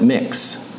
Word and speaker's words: Mx: And so when you address someone, at least according to this new word, you're to use a non-sexist honorific, and it's MX. So you pronounce it Mix.